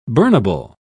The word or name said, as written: burnable